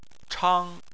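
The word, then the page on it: chang1: tone 1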